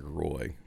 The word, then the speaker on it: Roy: like the Patrick Waz, the Osgoods, all those Western Conference goaltenders, and he was really the last one that was left. – I believe it's pronounced Patrick Roy.